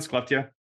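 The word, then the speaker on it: Skellefteå: Skleptia? Yeah. Haleftia. – Yeah. – You don't well, pronounce the S.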